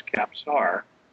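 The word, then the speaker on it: Kapsar: The name is Capsar.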